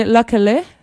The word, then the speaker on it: Lykke Li: La première, c'est une Suédoise du nom de Likely Lee, qu'on doit prononcer Luckily, pour ceux qui ont eu la chance déjà de me l'entendre prononcer dans des émissions antérieures.